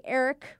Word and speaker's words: Erich: Next from, hey, Eric with the silent H. So it's spelled E-R-I-C-H.